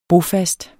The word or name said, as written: bofast